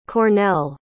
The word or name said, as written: Cornell